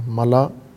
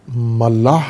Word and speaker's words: ملا: ملا، تلفظ درست کریں مومنین، سامعین سب، ملاح نہیں ہوتا، ملاح کشتی چلانے والے کو کہتے ہیں، ملاح نہیں ہے، یہ لفظ ہے نہیں ہے، میم لام الف حمزہ ملا، جس طرح خلا ہوتا ہے، خلا جس کو پڑھتے ہیں، خلا غلط تلفظ کر کے، خلا ہے، خلا، خلا ملا،